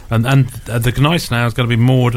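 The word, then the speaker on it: Gniezno: But instead of the Briskovitsa, we are getting the Gneis now. – That's it. Pronunciation. Oh. Perfect, yes.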